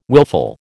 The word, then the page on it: wilful: American English